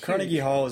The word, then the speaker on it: Carnegie Hall: Carnegie Hall.